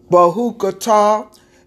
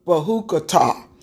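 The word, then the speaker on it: B'hukotai: It's called Bahukatah. It's spelled B apostrophe H U K O T A I. Bahukatah.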